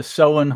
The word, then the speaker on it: Samhain: The holiday is pronounced Samhain, and it's spelled S-A-M-H-A-I-N, but it's pronounced Samhain.